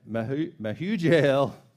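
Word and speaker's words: Mehujael: In verse 18, it says, Enoch had a son named Irad, and Irad became the father of Mahuel, and Mahujael. That's the same guy. I just pronounced it different.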